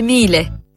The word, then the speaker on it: Miele: E ricorda, si scrive Miele, ma si dice Miele.